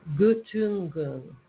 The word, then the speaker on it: Göttingen: But if you look at it in you know, when they do the IPA, Göttingen is how it's pronounced.